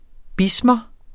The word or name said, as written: bismer